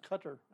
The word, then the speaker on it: Qatar: We've gotten listens from Qatar. – Okay. – Curacao. – I think it's pronounced Cutter, actually.